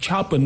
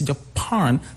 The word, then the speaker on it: Japan: Even the word Japan, the stress is not on the first syllable, like Japan, no, it's Japan, second syllable.